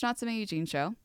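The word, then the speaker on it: Natsume Yuujinchou: You can also, you can watch Natsume Yuuhinchou. It's spelled N-A-T-S-U-M-E space Y-U-U J-I-N-C-H-O-U.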